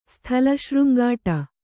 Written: ಸ್ಥಳಶೃಂಗಾಟ

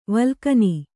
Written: ವಲ್ಗನೆ

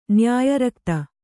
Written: ನ್ಯಾಯರಕ್ತ

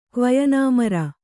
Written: ಕ್ವಯನಾಮರ

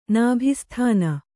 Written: ನಾಭಿಸ್ಥಾನ

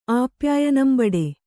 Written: ಆಪ್ಯಾಯನಂಬಡೆ

